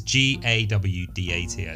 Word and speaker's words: Gawdat: I always say his second name wrong. I think it's Mo Gawdat. Gawdat. – Yeah. – Gawdat. – Yeah. – It's Gawdat. I think.